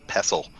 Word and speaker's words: pestle: Yeah, you don't even pronounce a T, it's just a pestle.